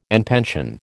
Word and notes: en pension: American English

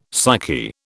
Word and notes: psyche: British English

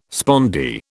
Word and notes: spondee: British English

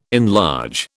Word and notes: enlarge: British English

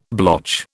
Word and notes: blotch: British English

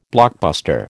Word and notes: blockbuster: American English

